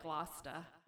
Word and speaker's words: Gloucester: So, that's not how they say it, though. It's more like Gloucester.